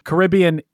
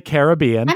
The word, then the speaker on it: Caribbean: How do you say it? I say Caribbean. – I do too. – You could also say Caribbean.